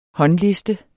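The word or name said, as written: håndliste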